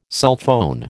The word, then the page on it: sulphone: American English